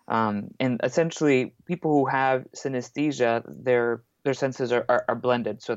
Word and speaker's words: synesthesia: There's actually an A in the middle of the word, so you mispronounce it like synesthesia. – Yeah. – But it's synesthesia, like anesthesia, but uh-huh.